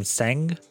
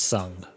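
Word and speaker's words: Tseng: I think in the last couple of episodes, I've been calling him Seng, but it's actually pronounced Sung, and I'm an idiot.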